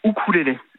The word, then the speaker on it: ukulélé: Alors normalement on dit ukulélé. Le U en océanien se prononce ou, bon. – Donc normalement on devrait dire ukulélé, c'est okay, la prononciation anglo-saxonne du mot.